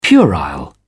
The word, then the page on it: puerile: British English